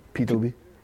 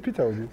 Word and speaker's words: Peter Obi: No, it's Peter Obi. – It's Peter Obi. Let me tell a peculiarity about the Igbo language. If one word ends in a vowel sound, and the following word starts with a vowel sound, they become one word. Emeka Ike is Emeki Ike. Peter Obi is Peter Obi.